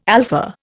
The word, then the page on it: alpha: Indian